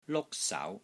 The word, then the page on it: LUK1 SAU2: Cantonese